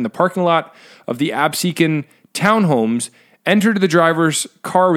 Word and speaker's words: Absecon: According to a release Saturday, the Absecon, I said that right, Jimmy? – Yep, Absecon.